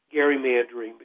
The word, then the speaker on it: gerrymandering: Then that brings us to what I still call gerrymandering, because that's how Elbridge Gerry pronounced his name.